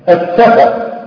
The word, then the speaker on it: السفر: ومعظم اللي ينطق كلمه زي السفر ينطق السين صعب. ينطق السين ايه؟ صعب. انظر الى نطق الصاد، الصاد الجل. فبعض الناس اذا نطق السين نطقها صاد، وهذا خطا.